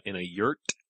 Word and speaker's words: yurt: And then, Jack went in and saw, an old one-eye shaman type person in a yurt. – Ooh, someone burned down. – Oh, yurt. That does rhyme. It kind of rhymes with girth.